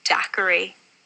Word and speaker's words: daiquiri: I am horrified at the thought of someone pronouncing it daiquiri. It's daiquiri. Always daiquiri.